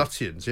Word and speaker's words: Lutyens: Is that how you pronounce his name, by the way? – Lutyens, yeah.